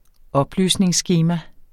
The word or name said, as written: oplysningsskema